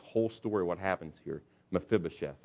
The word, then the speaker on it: Mephibosheth: Now you got to be able to pronounce that, Mephibosheth.